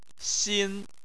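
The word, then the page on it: xin: xin1; tone 1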